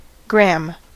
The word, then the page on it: gram: /ˈɡɹæm/; American English